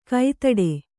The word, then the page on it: ಕೈತಡೆ: kai taḍe